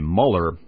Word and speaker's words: Mueller: Robert Mueller actually pronounces his name Mueller. Last name is spelled M-U-E-L-L-E-R.